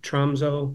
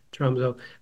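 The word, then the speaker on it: Tromsø: He grew up in the far north part of Norway in a town called Tromso. Well, that's how we usually pronounce it in the English world. Tromso.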